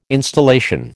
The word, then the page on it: installation: American English